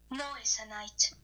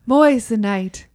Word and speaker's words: Moissanite: Moissanite. I said it right. – Moissanite.